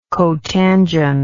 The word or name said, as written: cotangent